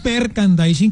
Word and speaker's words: merchandising: No, hay una mala pronunciación. Es merchandising, correcto, sí, ahí se.